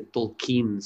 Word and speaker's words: Tolkien: Supostamente não é Tolkien, nós dizemos Tolkien, mas é Tolkien, supostamente, a pronúncia correta, ou mais correta.